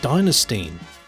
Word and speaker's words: Dynastein: It was Diner, the way you go to eat, and Stein, S-T long E with the end sound, Steen, ending my name. I hope that makes sense. So it's Dynastein, a bit like Dynasty.